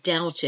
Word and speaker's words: doubt: I doubt it. D O U B, silent B, T.